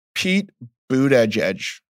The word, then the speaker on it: Pete Buttigieg: Pete Buttigieg.